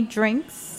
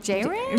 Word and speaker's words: Jrink: Can we just say it's spelled J-R-I-N-K? – Yes, exactly. It's spelled J-R-I-N-K. – Drink. – And after a few years, you guys know it's right to the point, it's drink. – Yes. – Yeah. I think in the beginning, everyone's like, J-Ring? – J-Ring.